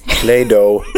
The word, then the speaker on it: Play-Doh: Ska vi ta bara sista då, hur man uttalar det här som hon rengjorde? Det hette Play-Doh.